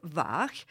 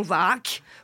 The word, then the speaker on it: Vaag: Il s'agit du groupe VAG, V-A-A-G, et non VAC, comme je l'entends prononcer par la plupart des francophones, wallons ou français. – Pas, ils ne disent pas VAC, ils disent vague. – Vague. – Vague. – Parce qu'un VAC, c'est vague. – Disons que la traduction, effectivement, c'est vague en français. Mais bon, ça ne se prononce pas vague, ça se dit VAG.